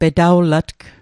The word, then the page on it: petawlatg: Mi'gmaq